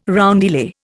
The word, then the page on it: roundelay: Indian